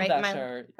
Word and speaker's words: Bescher: Milo Bescher. – Okay. That's right. Yeah. – Spell it. – B-E-S-C-H-E-R. – Okay. – M-I-L-O.